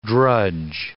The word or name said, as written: drudge